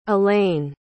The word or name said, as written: elaine